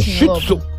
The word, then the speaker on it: Shih Tzu: Além de praticar o furto da moto, levou também o cachorrinho da, da raça Shih Tzu. Não sei se eu estou pronunciando certo. – Tá, é, tá é, certo, é, tá, é, certinho, é Shih Tzu.